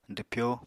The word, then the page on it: dwpyo: apatani